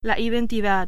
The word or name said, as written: identidad